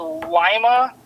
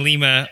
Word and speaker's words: Lima: He podiumed at Lima. Is that the correct pronunciation? – Yep. I almost slapped everybody that was saying Lima all weekend.